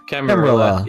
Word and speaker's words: Camarilla: Camarilla.